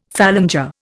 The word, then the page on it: phalanger: Indian